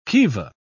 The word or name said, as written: kiva